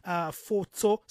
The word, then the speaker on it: Fotso: It's her name is spelled K-A-R-E-Y-C-E, Karese Foto. Surname is F-O-T-S-O.